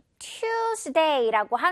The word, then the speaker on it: Tuesday: 자, 이것도 많은 친구들이 자꾸 Tuesday라고 하는 것 같아요. Q가 아니라 역시 오늘 학습 포인트대로 우 발음 내줘야 됩니다. 이제 Tuesday 이렇게 Tuesday. It's not Tuesday. 너무 T 발음 어색해요. 미국 영어 기준으로는 Tuesday.